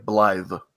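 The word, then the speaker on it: Blaidd: The way it's said in the game is like Blythe. – It is Blythe, right?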